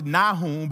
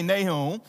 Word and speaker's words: Nahum: But today, the book of Nahum, some people pronounce it Nahum, but Nahum, I'm going to say Nahum in my southern accent, Nahum.